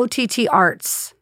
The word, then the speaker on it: OTTArts: OTTA. – Well, OTT Arts. Which is spelled with an A.